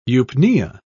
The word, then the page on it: eupnea: British English